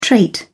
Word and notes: trait: British English

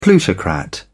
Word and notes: plutocrat: British English

